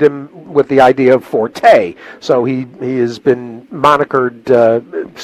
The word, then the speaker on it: Forte: Forte, because that's the way the owners want it pronounced. – Okay. All right, Forte.